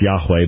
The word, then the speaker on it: Yahweh: name Jehovah, or more properly pronounced Yahweh, but since we're dealing with Mormons and they use the name Jehovah, that's how we will use it.